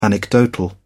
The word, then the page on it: anecdotal: British English